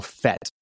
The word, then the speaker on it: FETE: And they actually have a process, an acronym that they use that they call FET, even though it sounds like F-E-T, but it is spelled, or excuse me, the acronym is F-E-T-E.